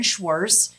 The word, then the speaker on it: Schwarz: I'm Karen Schwarz. My last name is spelled S-C-H-W-A-R-Z.